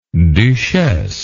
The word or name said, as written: duchesse